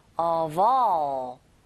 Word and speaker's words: of: Of의 V, 철자는 F지만 이게 소리가 V, 소리가 나거든요.